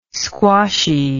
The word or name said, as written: squashy